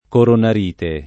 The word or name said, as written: coronarite